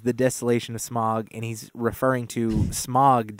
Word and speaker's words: Smaug: Smaug. – Smaug.